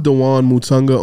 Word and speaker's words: Dewane Mutunga: I'm Dewane Mutunga on everything.